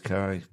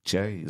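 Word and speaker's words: Kyei: I've thrown you there with the Che, haven't I? Because it's K-Y-E-I. – I always thought it was Kai, but it was Che, is it? – Yeah.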